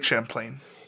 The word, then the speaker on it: Champlain: Maybe it was Lake Champlain. – Maybe it was Lake Champlain. – Champlain or Champagne? – It's Lake Champlain.